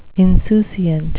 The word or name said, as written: insouciant